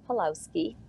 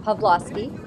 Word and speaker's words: Pawlowski: I'm also very pleased that Pastor Arthur Pawlowski, I've learned to say his name right. I always called him Pawlowski. And it's Pastor Arthur Pawlowski has been exonerated for this particular arrest.